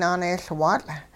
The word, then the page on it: naaneeyLwaatL': hupa